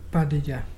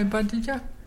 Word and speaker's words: Barraja: That is spelled B-A-R-R-A-J-A, but pronounced Badija. Can you say Badija?